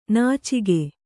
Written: ನುಚ್ಚಕ್ಕಿ

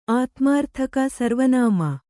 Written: ಸರ್ವನಾಮ

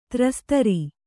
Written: ತ್ರಸ್ತರಿ

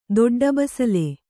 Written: ದೊಡ್ಡಬಸಲೆ

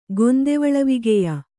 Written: ಗೊಂದೆವಳವಿಗೆಯ

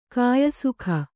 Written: ಕಾಯಸುಖ

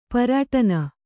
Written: ಪರಿಯಟನ

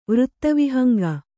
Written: ವೃತ್ತವಿಹಂಗ

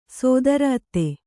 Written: ಸೋದರಅತ್ತೆ

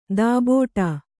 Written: ದಬ್ಬಾಡು

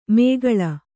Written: ಮೇಗಳ

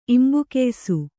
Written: ಇಂಬುಕೆಯ್ಸು

